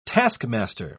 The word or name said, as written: taskmaster